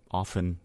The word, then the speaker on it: often: Should be often, right? Often, no T. – What's the correct? Can you look up the correct pronunciation is often without a T.